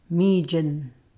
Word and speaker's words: mijin: The word to eat something inanimate sounds almost the same, but there's an N at the end. This is how you say to eat something inanimate. – Mijin.